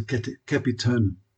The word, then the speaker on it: capitonym: And there's a quaint English word called capitonym, and I didn't even know how to pronounce it, C-A-P-I-T-O-N-Y-M, capitonym, which means a word that changes its meaning when it is capitalized.